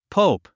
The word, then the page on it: pope: póup; ポウプ